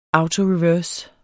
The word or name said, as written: autoreverse